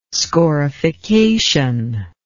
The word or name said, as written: scorification